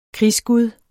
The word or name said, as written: krigsgud